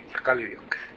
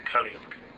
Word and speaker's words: Kaliuk: just so I can pronounce it correctly, I said Kuliuk. That's not correct. – Kaliuk. – Kaliuk. – Yeah. Kaliuk.